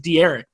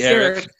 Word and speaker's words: Derek: Everybody pronounces it D-Eric. But no, it's just Derek.